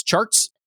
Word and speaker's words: Chartres: There's also a street called Chartres Street that they pronounce charts.